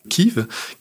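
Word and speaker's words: Kyiv: Et comment est-ce que vous l'avez appelée Kiev, mais ça je sais que c'est la prononciation russe. Exactement. Alors, ne t'inquiète pas, vous n'êtes pas les seuls. Hein. Euh, en France, on continue à dire Kiev et non pas Kiev, comme le font les Ukrainiens.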